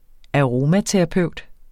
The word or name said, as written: AROMATERAPEUTEN